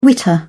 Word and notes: witter: British English

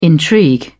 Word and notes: intrigue: British English

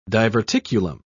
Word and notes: diverticulum: British English

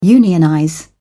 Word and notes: unionize: British English

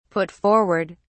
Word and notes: put forward: British English

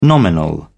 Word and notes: nominal: British English

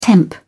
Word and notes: temp: British English

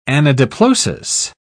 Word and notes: anadiplosis: British English

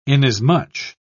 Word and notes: inasmuch: British English

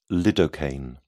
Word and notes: lidocaine: British English

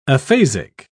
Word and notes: aphasic: British English

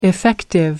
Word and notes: effective: American English

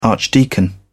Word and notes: archdeacon: British English

